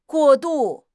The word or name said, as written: 过度